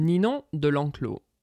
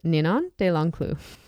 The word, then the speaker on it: Ninon de L'Enclos: Is that how you say it? – Ninon de L'Enclos. – Ninon de L'Enclos. – Yes.